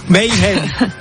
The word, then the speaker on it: Mayhem: Mayhem. – Pardon? – Mayhem. – Tu l'écris comment? – M-A-Y-H-E-M. – M-A-Y-H-E-M. – Okay. Mayhem.